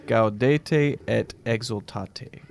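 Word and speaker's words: Gaudete et exsultate: Gaudete et exultate.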